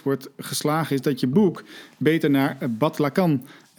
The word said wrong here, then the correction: Bataclan: uh, Bataclan. Bataclan. Bataclan.